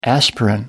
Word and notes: aspirin: American English

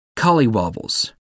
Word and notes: collywobbles: American English

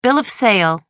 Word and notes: bill of sale: American English

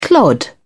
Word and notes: clod: British English

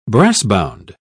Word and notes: brassbound: British English

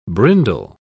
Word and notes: brindle: British English

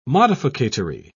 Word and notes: modificatory: British English

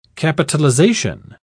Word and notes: capitalisation: British English

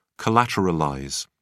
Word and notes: collateralize: British English

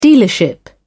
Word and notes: dealership: British English

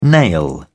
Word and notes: nail: British English

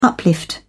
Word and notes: uplift: British English

